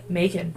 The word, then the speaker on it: Macon: Is that how you say it? Macon? M-A- it looks know. like bacon with an M. Okay. Macon.